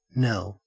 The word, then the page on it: knell: Australian English